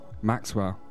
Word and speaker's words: MXXWLL: Next up, I've got a new release from Australian artist Maxwell. I'm going to go with, that's how you're going to say his name. – Yeah.